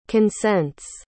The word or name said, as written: consents